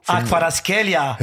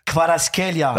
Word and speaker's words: Kvaratskhelia: Kvaratskhelia. – Who? Say again. – Kvaratskhelia.